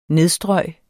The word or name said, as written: nedstrøg